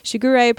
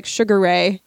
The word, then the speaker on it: Shigure: And our pronunciations will probably be off, although honestly, thank God for the second Fruits Basket animes, because I do not want to hear Shigure pronounced like Sugare ever again.